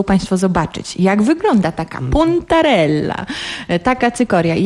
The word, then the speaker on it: puntarella: Ta cykoria, którą próbowaliśmy, to puntarella. Dobrze wymawiam? – Tak jest. To jest puntarella.